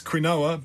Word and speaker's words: quinoa: It's spelled Q-U-I-N-O-A. Hence quinoa, but it's pronounced quinoa.